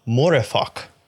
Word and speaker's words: Mooreeffoc: But Chesterton talks about the idea of Morefok, which is spelled M-O-O-R-E-E-F-F-O-C.